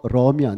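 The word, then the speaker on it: Durham: 어, 더럼이 아니고 더럼 D U R H A M인데 중간에 막 묵음이 되면서 더럼이라고 불리는 곳인데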